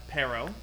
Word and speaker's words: Pero: I usually pronounce it Pero. – Pero. – Yeah, kind of like the fruit, pear. Okay. With a no on the end.